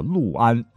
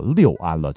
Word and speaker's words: 六安: 吴军多次进攻陆安，就是想夺取这个军事重镇，从而对魏国的中原核心地带构成巨大威。哎，怎么又聊到三国去了哈？总之，听完本期节目，我想你下回再看到陆安，一定不会再念作六安了，对吧？